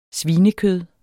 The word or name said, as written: svinekød